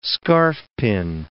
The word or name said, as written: scarfpin